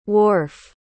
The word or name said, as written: worf